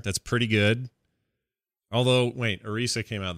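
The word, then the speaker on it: Orisa: Still Arisa.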